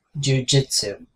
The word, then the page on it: jujitsu: American English